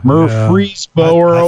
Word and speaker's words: Murfreesboro: And there's the first annual Great Duck Chase that's going to be held on Saturday, September 17th in Murfreesboro, Tennessee. I think I'm pronouncing that right. Murfreesboro. – I think so. – Murfreesboro. There we go.